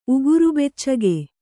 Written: ಉಗುರುಬೆಚ್ಚಗೆ

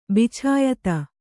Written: ಬಿಚಾಯಿತ

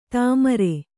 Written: ಧೂಮ್ರ

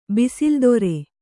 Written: ಬಿಸಿಲ್ದೊರೆ

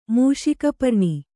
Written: ಮೂಷಕಪರ್ಣಿ

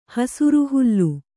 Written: ಹಸುರುಹುಲ್ಲು

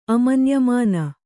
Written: ಅಮನ್ಯಮಾನ

